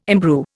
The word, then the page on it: imbrue: Indian